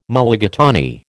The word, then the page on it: Mulligatawny: American English